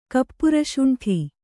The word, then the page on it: ಕಪರಸುಂಠಿ: kaparasuṇṭhi